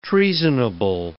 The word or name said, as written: treasonable